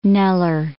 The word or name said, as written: Kneller